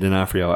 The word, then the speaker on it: D'Onofrio: I can't pronounce uh, Vincent D'Onofrio. There we go, Vincent D'Onofrio. I always mess it up, and I say D'Onofrio.